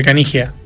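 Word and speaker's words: Caniggia: Lo digo bien, ¿no es Canilla? – Canilla, diría que nosotros siempre Canigia. En Argentina se pronuncia el italiano, todos los idiomas, como suena.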